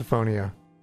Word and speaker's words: misophonia: It's misophonia. – Misophonia, thank you.